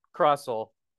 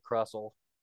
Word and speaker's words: Crustle: Is it pronounced Crustle? – I say Crustle. – Crustle? Oh, there we go. – Think like castle. Like, kinda I guess castle. You don't say castle. – I say crustle because I just say crustle. – Like soft T. – Well, I think I say crustle because of tussle. But that doesn't make any sense because it's spelled completely differently.